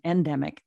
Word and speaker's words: endemic: But I also think that, because I think COVID is with us forever, and it's just going to be an endemic. Is that how you say it? Endemic? – Mm-hmm.